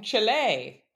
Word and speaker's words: Chile: Safe travels home from Chile. Did I say it right? – Yes.